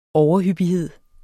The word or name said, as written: overhyppighed